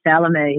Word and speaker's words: Chalamet: The King with, Timothy, Chalamet. – Chalamet.